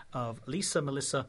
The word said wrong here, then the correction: Matassa: and her Christmas song? – Lisa Matassa. – Lisa Matassa. You see, this, I can't even pronounce the name properly.